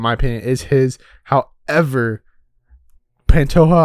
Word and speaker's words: Pantoja: And that is the champion in Brandon Moreno versus number 2 ranked Alexandre Pantoja.